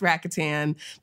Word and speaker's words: Rakuten: It's spelled Rakuten, but it's pronounced Rakuten.